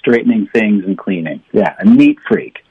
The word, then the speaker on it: neat freak: 应该是这样的对吧、今天就问到说哎他有洁癖吗 Is he a neat freak? – 好了今天教大家一个很炫的短语、mm-hmm. 叫做一个有洁癖的人。 – A neat freak.Freak. Yeah, stress the first word, a neat freak.